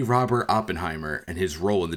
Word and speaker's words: Oppenheimer: Is it Oppenheimer or Oppenheimer? I'm constantly struggling with this. – The vast majority of people pronounce it Oppenheimer. – Oppenheimer, I think there might be some reason to think that Oppenheimer is also acceptable, but Oppenheimer is what I would go with.